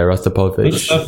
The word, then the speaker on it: Rostropovich: Yeah, Rostropovich.